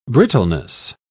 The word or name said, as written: brittleness